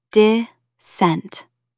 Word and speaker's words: dissent: Stress the second syllable. Dissent. Say it aloud.